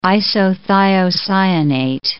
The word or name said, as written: isothiocyanate